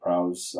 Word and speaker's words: Prowse: Uh, some sad news, Mr. David Prowse, I believe it's pronounced Prowse,